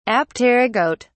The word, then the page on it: apterygote: British English